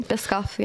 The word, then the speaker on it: Biscoff: Biscoff, yep.